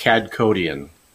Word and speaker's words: Kadkhodaian: It's pronounced Kadkhodaian.